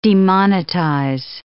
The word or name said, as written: demonetize